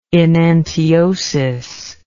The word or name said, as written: enantiosis